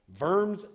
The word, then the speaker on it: Worms: But in Worms, Germany, it's actually spelled Worms, but they pronounce their W's, V's. Worms, Germany.